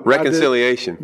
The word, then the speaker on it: reconciliation: And today we're going to be talking about reconciliance. I didn't say that right. – No. – Reconciliation.